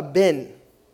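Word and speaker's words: ben (בן): La palabra hijo en el hebreo se llama, es la palabra ben. B-E-N, que se eh, pronuncia Ben, Benjamin, es un hijo de, mi fuerza.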